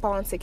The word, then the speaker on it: Fanta: You also can follow me on Facebook at Fanta Kante, and it's spelled F-A-N-T-A, last name K-A-N-T-E.